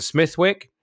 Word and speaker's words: Smithwick: The only place in the world where Smithwick is not pronounced Smithwick is in Ireland, where it came from, and there it's pronounced Smittick, like Smittick Ale.